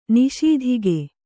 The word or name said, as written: ನಿಸ್ತೂಕ